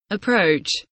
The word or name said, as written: approach